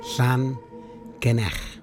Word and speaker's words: Llangenech: in Llanallach? I think that's how you say it. Llangenech. Llangenech. There you go.